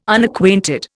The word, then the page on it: Unacquainted: Indian